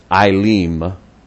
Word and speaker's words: Elim: When they came to, and I actually pronounced it wrong, I said Elim, I'm still working on my own Hebrew. It's Elim. E is long I, and the I is a long E.